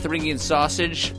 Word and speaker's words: Thuringian: And Thuringian sausage, I hope I'm pronouncing that correctly. – You are, and I've been there.